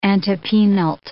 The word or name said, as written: antepenult